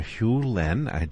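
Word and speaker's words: Hew Len: Dr. Hugh Len, did I pronounce that right? – Yeah, perfect.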